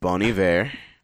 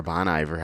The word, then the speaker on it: Bon Iver: Or Bon Iver, however you want to pronounce it. – Oh, Bon Iver. – But it's Bon Iver. I heard him pronounce it once. It was like French sounding.